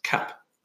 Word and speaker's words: CAP: SAP Cloud Application Programming Model is frequently abbreviated as CAP with a silent M, also in this documentation.